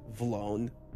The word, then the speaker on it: Vlone: Vlone. Do you know what Vlone is? – What? – Vlone? – You mean V-Lone? – Can you spell it? – excuse me, it's a V-Lone? – Yeah.